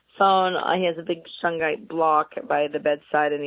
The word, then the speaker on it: shungite: So I also wanted to talk about shyite. Shyite is a stone from Russia. It's carbon-based. – You need to spell that. – S-H-U-N-G-I-T-E. – Shungite. There you go.